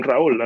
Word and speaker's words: Raúl: Like my name, for example. I recently worked with somebody who I was introduced to, and I said, hi, I'm Raul. And he was like, oh, I will not pronounce that. And I was like, okay. – What? Two syllables?